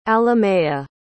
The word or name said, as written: alamea